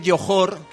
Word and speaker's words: Johor: Bueno, se dice Johor, que entiendo que no estéis muy familiarizados con la zona, pero se dice Johor, aunque se escribe con J, pero es Johor, es el príncipe de Johor.